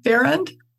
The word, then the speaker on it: Farrand: Farrand? – Farrand, yes.